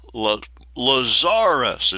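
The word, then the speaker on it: Lazaris: Lazarus, it's spelt like Lazarus, but you put the emphasis on the second symbol.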